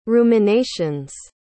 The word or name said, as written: ruminations